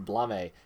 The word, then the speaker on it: Blame: I know it's spelled blame, but it's supposed to be. It's clearly supposed to be. Actually, it's probably supposed to be blamu, which is the sound a gun makes. – It's blam.